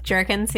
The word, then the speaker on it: Jerkins: Jerkins, yeah.